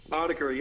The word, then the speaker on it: Autechre: but Autechre is the band's name. – That's how you say it? – Autechre, yeah.